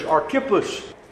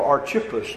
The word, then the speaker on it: Archippus: And there was another fellow servant, also likely to have been a preacher, is Archippus, or Archippus, depending on how you choose to pronounce it.